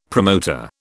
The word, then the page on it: promoter: British English